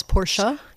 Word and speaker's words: Porsche: And by the way, I think the correct German pronunciation is Porsche.